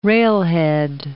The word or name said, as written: railhead